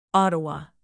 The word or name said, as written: ottawa